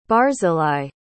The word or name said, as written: barzillai